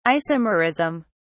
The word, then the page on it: isomerism: British English